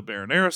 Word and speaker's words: marinara: all of the marinara sauce off. And the gentleman who dropped... How do you say it? Marinara? – Well, no. It sounded like you said baronara.